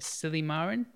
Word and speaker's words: Silymarin: It's like Silymarin? – Yes.